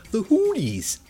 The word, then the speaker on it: Hooties: The Hooties. Which rhymes with cooties.